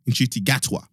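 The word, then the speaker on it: Ncuti Gatwa: And he is portrayed by Nchuti Gatawa. I hope I've said that correctly. Nchuti Gatawa. There we go.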